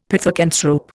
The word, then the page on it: pithecanthrope: Indian